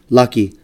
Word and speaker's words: Laki: And so he gave me the last four, L-A-K-I. Usually when I introduce myself, man, it's a Laki's. Lucky? Laki. Laki? You know what? Call me what you want, man. You know, so, but it's actually pronounced Laki Siaki.